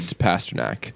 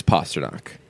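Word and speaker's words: Pastrnak: We do have an email, and it's somebody asking, because I am a big pronunciation guy, how do you pronounce David Pasternak? Not like that. It's Pasternak.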